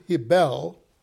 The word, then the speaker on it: Hebel: The name Hebel is the way the Hebrews pronounce it.